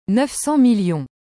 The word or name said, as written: 900000000